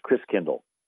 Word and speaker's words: Christkindl: And the German pronunciation of Christ child is Christkindl, where you get kinder care and kindergarten. Kind means child and Chris means Christ. And so over the years, Christkindl got pronounced Kris Kringle. So Kris Kringle is really Christkindl, which means Christ child.